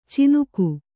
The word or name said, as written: ಚಿನುಕು